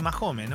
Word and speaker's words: Mahomes: ¿Cómo se dice? Mahomes. Mm, sí, Mahomes, ¿no? Mahomes. Sí, Mahomes.